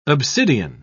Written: obsidian

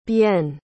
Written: bien

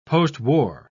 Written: postwar

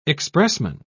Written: expressman

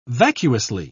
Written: vacuously

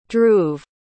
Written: dhruv

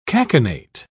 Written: cachinnate

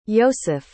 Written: yosef

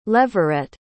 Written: leverett

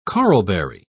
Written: coralberry

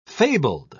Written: fabled

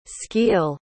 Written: skeel

